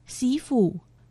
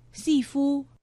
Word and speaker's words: 西湖: 客家话四线腔发音西府，海陆腔发音西夫。